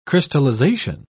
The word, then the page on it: crystallisation: British English